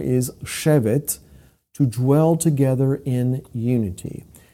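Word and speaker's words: Shevet (שבת): In fact, there's a very interesting word that, even though it stems from a different root word entirely, it's spelled identically to the word Shabbat, Shin Bet Tav. However, it's pronounced Shevet, Shevet.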